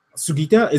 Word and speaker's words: Sugita: And I don't think it necessarily matches up to Sugita. Is that how you pronounce his name? The Japanese VA? – Sugita, is that how you pronounce it? Okay.